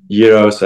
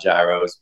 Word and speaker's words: gyros: Um, I mean, gyros, I think, first and foremost, that's how you say it over here. It's not gyros, but, uh, you know, those are probably number one on my list.